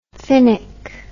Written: finnock

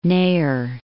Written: Neher

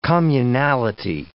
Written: communality

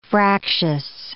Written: fractious

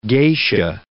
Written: geisha

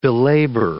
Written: belabor

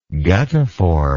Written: guttiform

